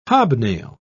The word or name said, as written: hobnail